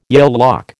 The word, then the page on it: Yale lock: American English